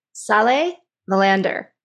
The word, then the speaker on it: Saleh Vallander: And this is, I'm going to see if I get the name right, Saleh Melander. How did I do? – Yes, great.